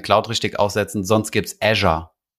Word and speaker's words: Azure: Azure oder wie spricht man das aus? – Azure. – Azure, ist es wirklich, schreibt man wirklich Azure. Azure, ja.